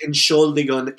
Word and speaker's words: Entschuldigung: It's pronounced Entschuldigung, and it means sorry in German.